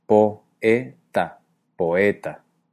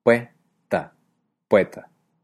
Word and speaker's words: poeta: Por ejemplo, poeta tendría 3 sílabas. Po-e-ta, poeta. Sin embargo, la realidad es otra y existen hablantes que en lugar de decir poeta con 3 sílabas, crean un diptongo, el diptongo we, y la dicen con 2 sílabas. Pue-ta, poeta.